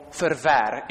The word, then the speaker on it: Verwer: It's something that I heard George Verwer said, or Verwer, if we're going to pronounce his name the Dutch way.